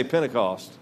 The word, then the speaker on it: Pentecost: Now you may pick up that I am doing a hard emphasis on the T because I'm from Louisiana and we say Pentecost. When we say it, it only has one T at the end. It doesn't have it anywhere else, but I'm trying to make sure I get all the T's in there.